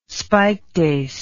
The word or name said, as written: spikedace